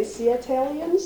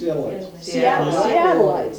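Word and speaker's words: Seattleite: Lots of, how do you say, Seattleians? Seattleans? – Seattleites. – Seattleites.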